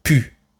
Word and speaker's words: plus: Vous avez entendu Dans cet exemple, le mot plus est prononcé pu. Le L du mot plus a complètement disparu et le S final n'est pas non plus prononcé.